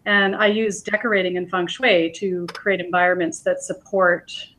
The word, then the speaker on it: feng shui: Feng shui? Am I saying that right? – Yeah, it's feng shui.